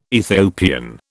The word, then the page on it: Ethiopian: British English